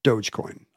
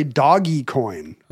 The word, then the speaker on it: Dogecoin: Dogecoin, according to its founder, is correctly pronounced Dogecoin. – Okay. – But plenty of people say Dogecoin, and some say Doggy Coin. – Oh, dear. – That's not, you wouldn't, there's one G.